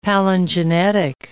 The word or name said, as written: palingenetic